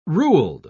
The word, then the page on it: ruled: British English